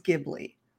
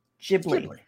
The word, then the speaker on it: Ghibli: Yes, the Italian word is Ghibli. But Miyazaki apparently did not care that it's supposed to be Ghibli, even though he's an airplane fanatic. He pronounces it Ghibli. Ghibli and Ghibli are written different in katakana in Japanese. They have both sounds in the Japanese language. If he wanted to say Ghibli, he could, but he doesn't. It's Ghibli.